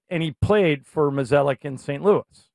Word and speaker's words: Mozeliak: If they keep, how do you pronounce, is it Molizak? – Mozeliak. – Mozeliak? – John Mozeliak.